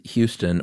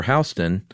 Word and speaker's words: Houston: I'm going with Houston. I think George is the only place in the world that pronounces it Houston. – Really? – Oh, wait, no, that's what they call that street in New York too, don't they? – Houston Street, yeah. You say Houston Street, New York, they're like, get a rope.